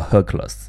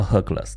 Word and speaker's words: Hercules: 我们今天先开个头啊，赫拉克勒斯啊，星座也叫 Hercules。那这个他希腊语的那个发音是赫拉克勒斯啊，等到他罗马神话里面的时候，就给他换了个名字啊，就成了 Hercules，其实就改了几个字母啊，呃，然后到天上之后呢，也叫 Hercules，但我们中文的翻译叫它五仙座啊。